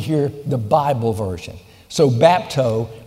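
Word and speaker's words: bapto (βάπτω): The first one in your notes is bapto, B-A-P-T-O. All right, that's the way it's pronounced from the Greek.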